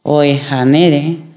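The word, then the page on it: mia: Swahili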